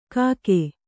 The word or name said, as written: ಕಾಗೆ